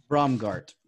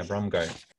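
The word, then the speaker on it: Braungart: how do you say it? Is it Brungada? Brungal? – Bromgart. – Yeah, Bromgart. And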